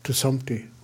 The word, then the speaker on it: Tsumpti: He was Fred Sumpter, but the correct pronunciation was Tsumpti, so I shortened it to Tsumpti with a hard T.